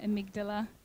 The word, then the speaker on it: amygdala: what happens is there's a response in our amygdala. Any medical people here? Can, I'm saying it right? Amygdala? Amygdala? Yes, good.